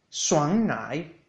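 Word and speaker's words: suan nai (酸奶): Vamos a por yogur, que en chino se dice suan nai, suan nai, suan nai, con un primer tono al lado de suan, suan, y un tercer tono al lado de nai, suan nai, suan nai.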